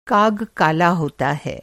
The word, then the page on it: काग: کاگ